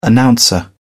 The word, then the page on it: announcer: British English